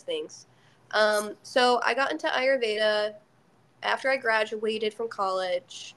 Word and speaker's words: Ayurveda: Yeah, so it's Ayurveda. – Ayurveda. – It doesn't sound how it's spelled, but it's like an I instead of an A.